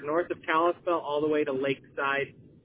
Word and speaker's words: Kalispell: And I see that Kalispell, am I saying that correctly? – Yep, Kalispell. Yep.